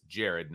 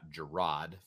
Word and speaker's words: Gerad: And one of the bonuses for Jared Parker is that, and it's Jared, not Gerad, for all of those who don't know how to pronounce names like the radio I was listening to on the way home.